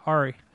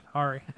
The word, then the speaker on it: Aury: A-U-R-Y. How do you say that? – A-U-R-Y. Yeah, Ari. That's what I'm going to go with. Ari.